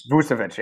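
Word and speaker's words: Vučević: Vucevic.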